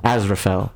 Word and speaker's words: Aziraphale: Aziraphale.